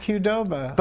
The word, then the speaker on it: Qdoba: It's pronounced Qdoba. – But it, with a U, it'd be Qdoba.